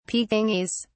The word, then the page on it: pekingese: British English